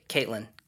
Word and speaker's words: Caitlin: Okay, let's go to how do we pronounce is this? Callan? What is it? – Caitlin. – Caitlin. Oh, okay. I missed the T.